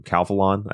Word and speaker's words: Calphalon: Because, well, imagine if there was a set of, I say it wrong every time, Calphalon, I think is the brand name.